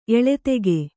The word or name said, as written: ಏಳಿದಿಕೆ